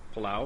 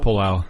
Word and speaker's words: Palau: Palau? – Palau.